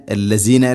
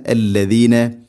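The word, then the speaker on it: അല്ലദീന (الذين): ലഖുല്ലദീന എന്ന് ആവരുത്. ലഖുല്ലദീന, അല്ലസീന അല്ല, അല്ലദീന.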